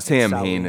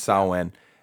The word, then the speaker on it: Samhain: Which that was one thing that I did appreciate was the fact that I was pretty young when I found out that the proper pronunciation of it's not Samhain, it's Samhain.